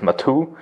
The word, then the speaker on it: Mato: The name of the product is Mato. Uh, is that the proper pronunciation? – Yeah, exactly. So in German we call it Mato.